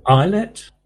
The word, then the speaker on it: Ilett: Julian Iliet, I-L-E-T-T. How would you pronounce that? Iliet. – Islet? – Yeah.